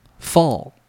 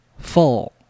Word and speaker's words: fall: 如果把这一个 L 放在后面的时候呢，我们就要念成像欧阳修的欧这个字。那么请您试一下 fall fall。